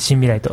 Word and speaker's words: 森未来: あ、はい、森の未来と書いて新未来と読みますのでよろしくお願いします。